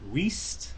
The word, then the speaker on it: Wiest: Does she pronounce it West? – No. – Oh, God. – No. No, she doesn't. – Diane... – I figured it was just a little I in there.